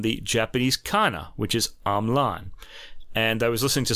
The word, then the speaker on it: Hamelin: But Russ has discovered a video of Marc-Andre himself pronouncing his name as Hamelin.